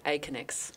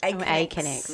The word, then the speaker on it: Aconex: Chief People Officer of Aconex. Have I pronounced that properly? Because it goes a bit weird in my mouth. – It is. It's actually hard to say. Aconex. – Aconex.